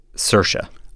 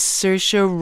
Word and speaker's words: Saoirse: I have a piece of paper in front of me in which I have written capital S-I-R-S-H-U-H. – Saoirse. – Very good. The Irish Best Actress nominee's name is Saoirse Ronan. Saoirse is spelled S-A-O-I-R-S-E.